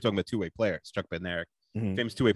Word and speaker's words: Bednarik: There's a sprinter named Kenny Bednarik. Okay. – Kenny Bednarik. – Regular college kid, very good. He won the silver medal in the 200 meters. He was calling him Bainerak, like the D is silent.